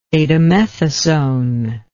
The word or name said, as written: betamethasone